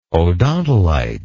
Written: odontolite

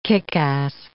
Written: kick-ass

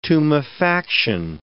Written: tumefaction

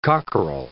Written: cockerel

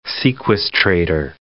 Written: sequestrator

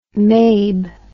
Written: mabe

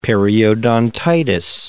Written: periodontitis

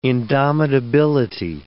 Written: indomitability

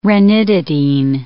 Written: ranitidine

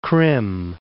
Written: Krim

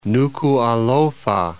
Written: Nuku'alofa

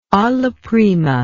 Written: alla prima

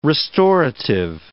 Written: restorative

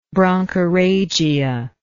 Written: bronchorrhagia

